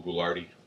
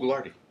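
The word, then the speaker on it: Ghoulardi: If not, just Google Goulardi. Google Goulardi. – Google Goulardi. – Say that three times fast. – So it's Ghoul and an Arty, and that's how you spell it.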